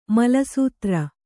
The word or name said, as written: ಮಲಸೂತ್ರ